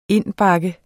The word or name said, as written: indbakke